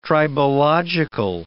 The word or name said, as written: tribological